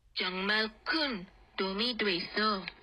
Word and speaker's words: 됐어: Okay, so make that distinction and pronounce it like this. 정말 큰 도움이 됐어. 됐어.